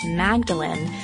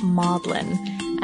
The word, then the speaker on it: Magdalen: In our Oscar Wilde podcast, we said the Oxford College of Magdalene, like Mary Magdalene, it should be pronounced Maudlin.